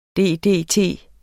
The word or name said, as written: ddt